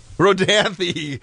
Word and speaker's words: Rodanthe: R-O-D-A-N-T-H-E. Rodanthe. Help me out on that one. – I heard that. Rodanthe.